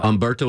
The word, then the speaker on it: Umberto: Umberto with a U or an H? – With a A silent H.